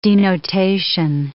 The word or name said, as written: denotation